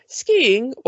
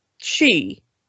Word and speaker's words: ski: But it turns out that apparently the original pronunciation of ski was she. Like, apparently the like Swedish and Norwegian and the modern version of that became she, even though the Old Norse was skis.